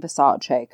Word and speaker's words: Versace: He's like, oh, Carrie, why don't you come check out these Versace, which shout out to him for pronouncing Versace correctly.